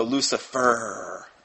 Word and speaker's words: Lucifer: They like to be called children of Lucifer with the stress on the last syllable. So Lucifer, evidently, is how they like to fancy themselves being called.